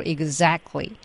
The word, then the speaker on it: exactly: 我也有一直有类似的问题没有得到解决，比如说两个爆破音加上非爆破音的情况下，比如 exactly，我听美国人一般都会完全省略 t 而保留 k 音，实际发音就是。 – Exactly，我也感觉这样发音要容易得多。